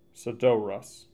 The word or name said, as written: Sadorus